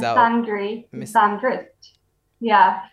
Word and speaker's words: misandry: No, I'm sure it's misandry. Like misogynist. – Yeah. – Misandry, I would stress the same syllable, right? – Yeah.